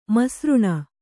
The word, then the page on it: ಮಿಸ್ರಾನಿ: misrāni